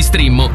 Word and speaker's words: Streammo: Entra adesso nel mondo di Strimmo. Vai su strimmo.it. Si scrive Streammo o scarica l'app.